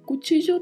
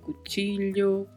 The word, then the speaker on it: cuchillo: Cutígio tem duas particularidades de pronúncia. Primeiro, o CH. Esse CH é pronunciado com o som que nós utilizamos para falar a palavra tchau em português. Então, falamos cutígio. E o duplo L, que também já mencionamos, que pode ser pronunciado de várias formas, né? Pode ser cutígio, cutilho, cuticho.